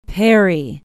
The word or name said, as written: perry